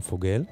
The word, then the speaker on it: Fagelle: כותבים את השם שלה F-A-G-E-L-L-E, אבל מבטאים פוגל. וזה קטע שלה עם מוזיקאי שוודי נוסף בשם פוסטרם. פוגל ופוסטרם.